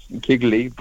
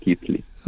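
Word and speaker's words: Keighley: But, no, it's written Kigley, but it's pronounced Keithley.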